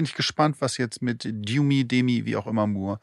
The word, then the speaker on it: Demi Moore: Ich bin gespannt, ob Demi Moore, die wird übrigens nicht Demi Moore ausgesprochen. Sie hat jetzt erzählt, dass sie muss das immer wieder korrigieren. Sie heißt Demi, warum auch immer. Jedenfalls Demi Moore, ob die für The Substance für einen Oscar nominiert wird.